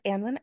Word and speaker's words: Annwyn: So my name is Anlyn Avalon. I am a water priestess and Celtic witch. You can find me on Instagram under Anlyn Avalon. It's just like my name is spelled, A-N-N-W-Y-N, Avalon.